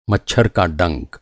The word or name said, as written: मच्छर